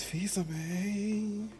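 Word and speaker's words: Fils-Aimé: The fact that Google Assistant was Reggie Fils Amy. It's Fils Amy.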